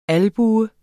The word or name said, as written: albue